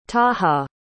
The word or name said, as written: taha